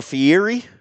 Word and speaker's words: Fieri: But one name that definitely stuck out to me out of the original crew in 2012 was Guy Fieri. – Is it Fieri or Fieri? – It's Fieri. – Fieri? It's spelled okay. Fieri. It's spelled Fieri, but it's. He refers to himself as Fieri.